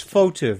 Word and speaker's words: Fauteux: It's hard to pronounce. – F-A-U-T-E-U-X. FOTU. Very easy.